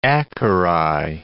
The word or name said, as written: acari